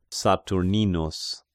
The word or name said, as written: Saturninus